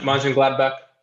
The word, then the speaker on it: Mönchengladbach: Mönchengladbach.